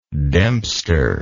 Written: dempster

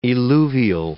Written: eluvial

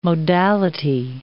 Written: modality

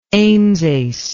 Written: amesace

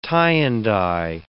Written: tie-and-dye

